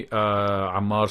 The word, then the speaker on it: Ammar: So if people want to reach me, Ammar Sroor at LinkedIn.